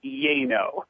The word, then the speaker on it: yay-no: You know, yeah, it's actually a term that I coined, which is a fancy way of saying yes, no, but it's pronounced yay-no. – Yay-no, okay.